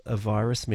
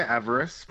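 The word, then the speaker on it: AVIRIS: Now, I hope I'm saying it right. What is the NASA JPL a virus mission? – Yeah, we say avarice. – Avarice. – But that's okay. Yeah.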